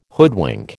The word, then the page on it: hoodwink: American English